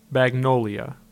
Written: Magnolia

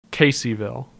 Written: Caseyville

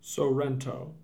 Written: Sorento

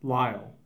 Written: Lisle

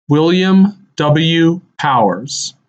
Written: William W. Powers

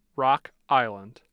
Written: Rock Island

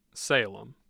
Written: Salem

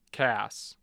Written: cass

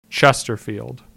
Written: Chesterfield

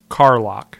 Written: Carlock

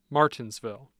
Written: martinsville

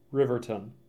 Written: Riverton

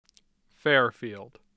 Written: Fairfield

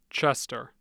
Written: chester

